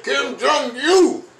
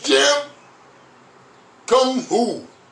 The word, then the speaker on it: Kim Jong-un: If Kim Jong-un, get it right, Kim Jong-un. You get his name wrong, he might send a missile at you. – That's true, he might. – So you might want to get his name right.